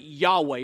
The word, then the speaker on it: Yahweh: And some people put an A after the Y and an E after the W. They pronounce it Yahweh.